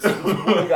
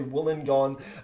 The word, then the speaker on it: Wollongong: So I just get all these texts on the text line on Triple J being like, no, dickhead, it's Wollongong. And they'd spell it for me. But they'd spell it for me, but you couldn't send an audio. So I'd be like, oh, sorry, Brendan, I mispronounced. It's actually Wollongong.